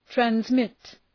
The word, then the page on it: transmit: trænz’mıt, træns’mıt